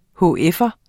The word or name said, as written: hf'er